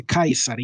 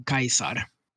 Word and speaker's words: Caesar: Ja, Mm, eh, och eh, även, eller Caesar, det uttalas ju Kaiser egentligen, för det finns inget mjukt C i latin. han själv skulle ha uttalat det som Kaiser.